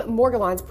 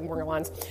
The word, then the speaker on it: Morgellons: The name Morgellons, pronounced with either a hard or soft G, unless you're on more Morgellons.